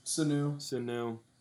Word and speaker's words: Sanu: Sanu.